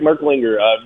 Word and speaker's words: Merklinger: Jake Merklinger, is that how you pronounce his name? – Yeah, Jake Merklinger, very interesting and intriguing prospect.